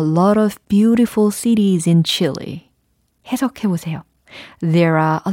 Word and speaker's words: Chile: 그러면 어 칠레라고 하면 되지 않을까 생각하시겠지만 그게 아닙니다. 잘 들어보세요. chili, chili, chili. 아, 칠레가 아니라 chili. 라고 발음이 되는군요. 그죠? 물론, 어, chili sauce라고 할 때 그 chili하고 동일한 발음입니다.